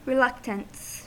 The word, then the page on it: reluctance: American English